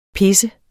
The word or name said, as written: pisse